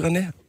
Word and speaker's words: René: Han udtaler det simpelthen på den rigtige måde, og min lillebror han sagde, der kan du bare se, det er sådan, man skal sige René.